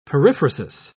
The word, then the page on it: periphrasis: British English